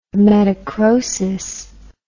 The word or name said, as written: metachrosis